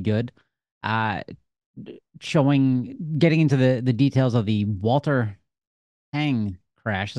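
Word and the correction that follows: Huang: Is that how I pronounce his name correctly? – It's Wong.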